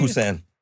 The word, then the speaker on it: Poussin: Poussin. It's spelled P-O-U-S-S-I-N. – Okay. – It's French. So poulet, P-O-U-L-E-T, is chicken.